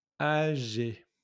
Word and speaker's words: âgé: So, âgé with le chapeau, the little euh, chapeau, like a hat accent on the A, âgé, then G and then euh, E accent. Donc, l'accent euh, aigu. Accent aigu, that's the accent that goes up.